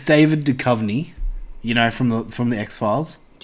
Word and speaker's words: Duchovny: David Bukovy. – It's not even a B. It's a D. David Duchovny.